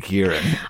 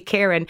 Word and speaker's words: Kieran: I was like, oh, I must be pronouncing it wrong. It can't be Kieran. It must be Kieran. – So yeah. I just thought everyone was Kieran.